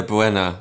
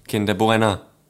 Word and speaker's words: Kinder Bueno: So I would say Bueno. As it should be pronounced, I'm pretty certain of that. But Jordan, to this day, will say "Kinder Bueno," as if it rhymes.